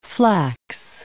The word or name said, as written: flax